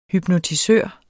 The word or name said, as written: hypnotisør